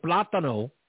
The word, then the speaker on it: plátano: So plátano is how you pronounce it.